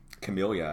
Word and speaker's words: Camellia: We can talk about the Camilla... – Camellia, I thought.